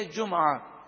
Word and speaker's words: جمعہ: سورۃ الجمعہ، لفظ جمعہ یہ ہمارے عرف میں جمعہ مشہور ہے، تو لفظ جمعۃن بھی ہے اور جمعہ بھی ہے، تو اس لفظ کو دو طرح سے عربی میں پڑھا گیا، جمعہ میم پر پیش اور میم ساکن بھی ہے جمعہ، دونوں تلفظ درست ہیں.